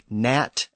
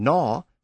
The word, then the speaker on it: gnaw: And words like gnat and gnaw have a G that was once pronounced at the front, but it's also disappeared.